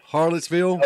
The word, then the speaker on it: Hallettsville: Chip has offices in Austin, uh, Schulenburg, uh, Flatonia, and Hallettsville.